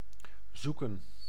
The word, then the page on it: zoeken: [zu.kǝː]; /ˈzu.kə(n)/